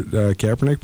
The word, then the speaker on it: Kaepernick: I mean, it would be great to see Colin Kaepernick. Can I say his name right? – Yes, you almost got it. Kaepernick, yeah.